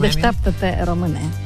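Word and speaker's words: Deșteptă-te, române: Deșteptate Române.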